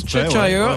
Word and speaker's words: Cheshire: Cheshire.